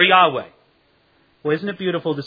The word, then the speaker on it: Yahweh: Actually, it's more biblically correct, we would say, to say Yahweh. Jehovah is actually an old and somewhat incorrect pronunciation, but we won't quibble about that.